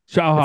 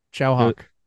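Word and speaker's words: Tsui Hark: With an produced by, now I should learn how to say his name, Tsuhark? – Tsuhark. – Chow Hawk. It's like Chow. Think about when you're eating with a bird. Chow Hawk.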